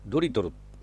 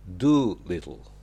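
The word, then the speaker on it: Dolittle: ドリトル、なんか変な名前ですよね。ところがイギリスじゃどこでもあるような名前みたいです。ドリトルって読むんじゃなくて Do Little、 L が入るからちょっと発音が難しいです。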